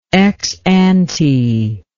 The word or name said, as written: exante